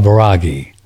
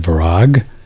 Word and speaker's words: Vairagi: is a concept called Vairagi. V-A-I-R-A-G-I. V-A-I-R-A-G-I. Vairagi. Some people pronounce it Virag. It's pronounced both ways, Vairagi.